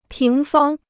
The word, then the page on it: 平方: píng fāng